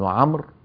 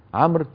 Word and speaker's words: عمرو: طيب، ثم قال رحمه الله: حدثنا أبو بكر عبد الله بن أبي داود قال حدثنا أبو الطاهر أحمد بن عمرو، عمرو تكتب واوها ولا تلفظ، لا تقول عمرو،